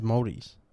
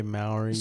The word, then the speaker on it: Māori: Because who's listening to it besides Māoris? – Yeah, yeah. It's just... – Or Māoris, I don't know. – You'd say Māoris. – I always hear Māoris and I also hear Māoris.